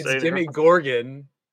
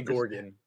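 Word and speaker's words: Demogorgon: It's Demi Gorgon. – Demi Gorgon. Sorry. – Yeah, it's like a guy named Gordon who goes by Demi. Demi Gorgon. – Demi, can't even say it wrong. Demi Gordon. That's what you. – Gorgon. Demi Gorgon. – Gordon or Gorgon. – Gorgon. – It's Gorgon. Michael keeps saying Gordon.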